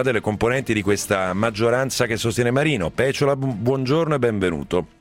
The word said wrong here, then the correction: Peciola: Peciola, abbia pazienza, mi hanno segnalato l'accento giusto.